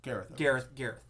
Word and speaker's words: Gareth: a lot of people have been saying that Garth Edwards, who directed Godzilla, his version. – Gareth, thank you.